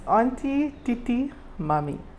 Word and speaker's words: Auntie Titi Mummy: So your work in particular, and excuse any mispronunciations along the way, there's one work called Auntie Tai Tai Titi? – No. – Tia Tia? – It's Auntie Titi Mummy.